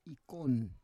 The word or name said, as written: ikon